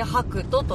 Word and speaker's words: 白兎: なのでちょっとねこれはどこだろうどこだろうと思っていたら白いウサギの神話で白兎が白いウサギと書いて白兎と読むんですけれども、わー砂浜綺麗だー。